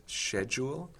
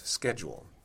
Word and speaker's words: schedule: So we go from a C spelling to an S-C-H spelling, and then we have a choice. Do we pronounce it as the yod-coalesced version of schedule, that is, schedule? There are certainly historically S-C-H words in English that, as I said before, that are pronounced sh. So that's a valid arm. But then there's a sort of Greek spelling-influenced sk, as in scholastic, which yields schedule.